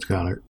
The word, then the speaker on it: Goedert: Now, what makes the Eagles so interesting is that their other tight end, Dallas Goedert, however you pronounce his last name. – I think it's Goddard.